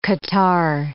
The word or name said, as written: catarrh